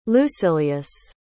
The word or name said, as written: lucilius